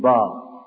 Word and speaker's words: با: نمیگیم ب، میگیم با.